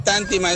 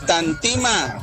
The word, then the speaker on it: Tantima: Emiliano, no es Tántima, es Tantima.